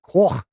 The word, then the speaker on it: Coch: So far, it's spelled Coch, C-O-C-H, means red in Welsh, but it's pronounced like Corfe. Something like that.